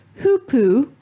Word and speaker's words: Hoopoe: The River Hoopoo. That's how you say it. The Hoopoo. – Is it the Hoopoo or is it the Hoopoe? – Hoopoo.